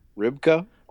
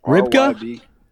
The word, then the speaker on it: Rybka: Ribkin, how would you say that? – Rybka. – Rybka. – R Y B K A.